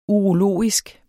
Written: urologisk

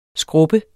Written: SKRUBBES

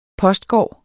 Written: postgård

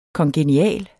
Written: kongenial

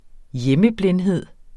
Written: hjemmeblindhed

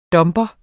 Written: dumper

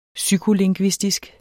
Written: psykolingvistisk

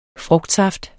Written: frugtsaft

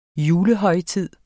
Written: julehøjtid

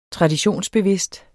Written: traditionsbevidst

